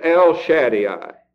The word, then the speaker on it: El Shaddai: The first of these words is El Shaddai. E-L, and then S-H-A-D-D-A-I. El Shaddai, or just El Shaddai, as some pronounce it.